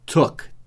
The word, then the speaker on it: took: Take é o presente, T-A-K-E, take, e o passado? Took, T-O-O-K, é um verbo irregular, então ele muda. O presente é take e o passado, took, T-O-O-K, took. Took, book, igual book, né? Livro, book, eu estou falando da pronúncia. Book, B-O-O-K, e took. T-O-O-K.